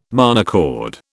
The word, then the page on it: monochord: British English